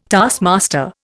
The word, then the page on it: taskmaster: Indian